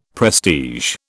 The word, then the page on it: prestige: British English